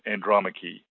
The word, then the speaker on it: Andromache: So, for example, the name Andromache, which ends in an E, has a fourth syllable, and any name which ends in a final E.